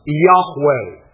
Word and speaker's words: Yahweh: And with the vowel pointing it would be pronounced Yahweh.